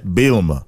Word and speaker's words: Bielema: So you brought up Coach, I want to say it right, Brett Bielema.